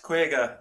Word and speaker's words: Kwayga: Now, Kwayga, I'm presuming that is a play on the Irish word for 50, even though it's spelled K-W-A-Y-G-A. – Yes, Kwayga. It is indeed.